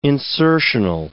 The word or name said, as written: insertional